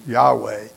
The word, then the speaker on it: Yahweh: We don't even know sure how to pronounce it. Current view is Yahweh.